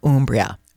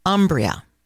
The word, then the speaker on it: Umbria: So, you know, before I carry on, I just want to mention the pronunciation of the word Umbria, which is normally, or sometimes I should say, mispronounced by English-speaking people, and they call it Umbria.